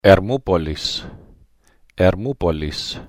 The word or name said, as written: Hermoupolis